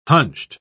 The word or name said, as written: hunched